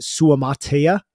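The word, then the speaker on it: Suamataia: His name is Kingsley, what is it, Suamatea, I believe is how you say it. – I believe that's correct, yes.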